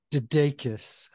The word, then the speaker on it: DeDakis: DeDakis.